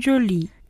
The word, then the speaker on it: jolis: Les stylos sont jolis. – 그쵸, joli에는 s가 붙지만 발음이 변화가 없기 때문에 앞에 있는 명사로 단수와 복수를 구분하시면 됩니다.